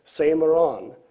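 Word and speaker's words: semeron (σήμερον): The word translated "today" comes from the Greek word pronounced "semeron," is an adverb of time and generally means now, or this night, or this day.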